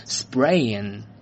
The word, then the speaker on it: sprain: 这个词呢，它就可以表示扭伤，它既可以做动词，又可以做名词，sprain。注意啊，这个词呢，它的这个 p 的发音呢是浊化声 b，所以呢要念成 sprain，而不是 sprain。注意啊，不要发成 sprain，OK？sprain 表示扭伤。